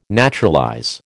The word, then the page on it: naturalize: American English